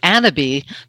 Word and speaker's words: Annabis: Well, the company, we pronounce it Annaby because that's a fancy French way, and we're fancy.